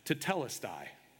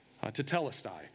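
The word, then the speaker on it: tetelestai (τετέλεσται): It's pronounced tetelestai, tetelestai.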